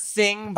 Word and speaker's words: Xing: Because I mean, I see Zing is X-I-N-G. – We pronounce it Sing, but Sing, whatever.